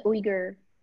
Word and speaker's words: Uyghur: about the oppression of the Uyghur. Did I say the name right? Yeah.